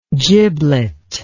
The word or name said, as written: giblet